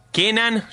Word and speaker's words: Kenan: Kenan, ya viste, tipo el Ken.